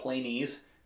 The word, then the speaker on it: Planes: So there are a genus of ocean-dwelling crabs called Planese crabs, just spelled like planes,